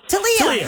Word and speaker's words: Talea: T-A-L-E-A. How do you say your name? – Talia.